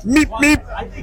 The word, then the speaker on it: Beep Beep: It sounds like Meep Meep, but it's spelled Beep Beep.